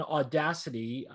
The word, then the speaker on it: Audacity: And you hit on Audacity. That's what it's called, right? – Yeah.